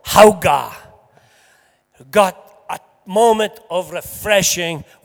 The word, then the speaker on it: Hauge: It's spelled H-A-U-G-E, but pronounced Hauga.